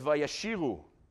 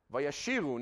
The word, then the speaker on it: Vayashiru: Vayashiru. Now, here the pronunciation of vayashiru is not exactly the same pronunciation you'd use if you were using it to say this, to say the word sing.